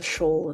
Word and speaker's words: shoal: But I think it is a shoal of fish. – What is it? Shawl? – Shawl. Shawl. Shawl. – Shawl. Shawl. Rhymes with Joel.